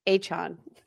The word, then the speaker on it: Achane: Achane.